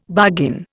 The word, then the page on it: bargain: Indian